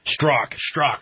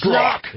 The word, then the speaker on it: Strzok: Strzok. – Thank you. – Strzok.